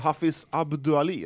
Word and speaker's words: Hafiz Abdoualli: Så man har ju haft Hafiz Abdoualli, eller? – Ja, exakt. Bra uttal där.